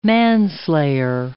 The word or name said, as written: manslayer